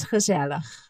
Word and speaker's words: gezellig: It's gezellig.